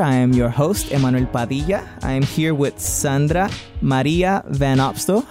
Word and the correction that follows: Van Opstal: How do you pronounce the last part of that? – Van Opstal. – Van Opstal.